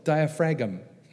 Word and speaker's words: diaphragm: And it's such a fancy muscle that there's a silent G in it as well. Diaphragm.